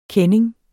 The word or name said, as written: kending